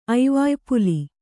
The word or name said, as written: ಐವಾಯ್ಪುಲಿ